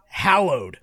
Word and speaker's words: hallowed: It's pronounced hallowed. And I was like, that's how this word works, guys. Hey, movie makers. Hollowed is a different word.